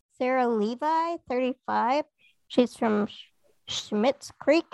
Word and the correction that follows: Schitt's Creek: Is that how you say it? – Oh, Schitt's Creek.